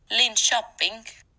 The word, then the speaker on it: Linköping: Linköping.